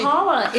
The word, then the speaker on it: challah: I learned how to bake a challah. I did it. Maybe I'm saying it wrong. No, it's a challah. It's challah.